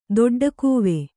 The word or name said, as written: ದೊಡ್ಡಕೂವೆ